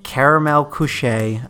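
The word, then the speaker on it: Karamell-Küche: We're stopping in Caramel Coucher. I think that's how you say it.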